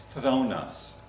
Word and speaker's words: phthonos: Phonetically, it's phthonos. P-H-T-H. My mouth doesn't do that.